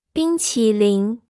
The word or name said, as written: 冰淇淋